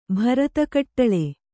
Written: ಭರತಕಟ್ಟಳೆ

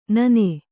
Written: ನೂನು

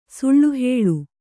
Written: ಸುಳ್ಳುಹೇಳು

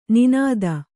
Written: ನುಣ್ಣಿತು

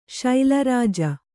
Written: ಶೈಲರಾಜ

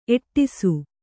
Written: ಏಡಿಸು

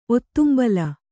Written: ಒತ್ತುಂಬಲ

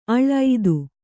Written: ಅಳ್

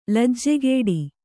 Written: ಲಜ್ಜೆಗೆಡು

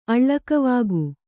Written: ಅಳ್ಳಕವಾಗು

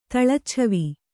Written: ತಳಚ್ಛವಿ

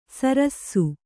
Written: ಸರಸ್ಸು

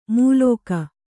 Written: ಮೂಲೋಕ

